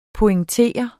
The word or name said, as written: pointer